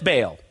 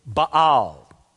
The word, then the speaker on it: Baal: Friends, from my studies, I think I have learned that the correct pronunciation of the name of that pagan god is Baal. But for some reason, very early in my Christian life, it got ingrained in me that his name is Baal.